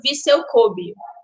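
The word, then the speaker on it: Vissel Kobe: Então é sempre aquela vergonha alheia para falar aqui, mas esse é mais ou menos tranquilo, tranquilo porque é Viseu Kobe. A gente chegou a essa conclusão de que é assim que se pronuncia.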